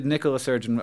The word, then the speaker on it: Nicola Sturgeon: It Nicola Sturgeon, a woman.